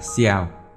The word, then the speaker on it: ciao: Lời chào của tiếng Ý viết là ciao, cách phát âm tương đối giống tiếng chào của Việt Nam – những người tôi gặp trên đường,